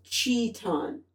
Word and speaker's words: Chiton: You're saying its name wrong. – How do you spell? How do you say it? – Chiton. – Chiton. – Chiton. – Chiton. Okay. So forget the whole Chiton thing. It's Chiton.